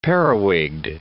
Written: periwigged

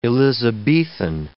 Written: Elizabethan

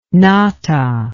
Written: Natta

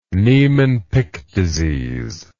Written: Niemann Pick disease

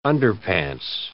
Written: underpants

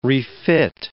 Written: refit

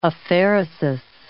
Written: aphaeresis

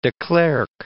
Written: de Klerk

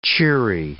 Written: cheery

